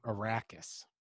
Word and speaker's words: Arrakis: Arrakis, I think.